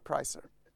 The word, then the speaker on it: Pricer: Du har skrivit en aktierekommendation om ett bolag som heter Priser fast stavat med C och uttalat Pricer.